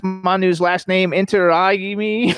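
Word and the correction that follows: Intiraymi: Close enough. Manu Inter-Raimi. – Inter-Raimi. There we go.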